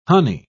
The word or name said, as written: honey